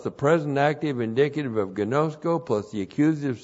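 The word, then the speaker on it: Gnosko (γινώσκω): And then we have the verhina plus the present active indicative of gnosko. Gnosko, G-I-N, long O-S-K, long O, gnosko.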